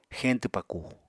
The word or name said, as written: xeñtw pakù